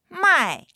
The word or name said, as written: mai4